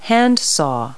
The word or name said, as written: handsaw